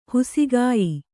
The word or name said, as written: ಹೊಸಗೆಯ್